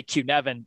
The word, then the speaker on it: Cynefin: So I recently came across this decision-making framework that's called Cynefin, and it's spelled Cynefin, so nothing like what you would say reading it and say Cynefin, and that is because it is a Welsh word for habitat or maybe familiar.